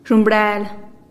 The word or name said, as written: zhumbrel